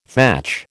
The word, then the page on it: thatch: American English